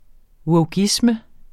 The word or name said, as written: wokeisme